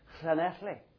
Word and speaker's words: Llanelli: Now, it looks like Llanelli. It's double L-A-N-E-double-L-I. And people talk about Llanelli. Llanelli is not the pronunciation for that town. It's Llanelli. And you have to get it right.